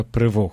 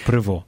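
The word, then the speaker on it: Prevost: Кардинал, будучи кардиналом, наш папа носив ім'я Роберта Франциска Прево, хоча пишеться Превости, але оскільки батько в нього був французом, тобто це французьке прізвище, по-французьки ці слова вимовляються саме так, так? Тобто Прево.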